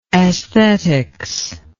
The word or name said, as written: esthetics